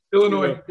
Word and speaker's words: Illinois: How do you say Illinois? – Illinois. Yeah.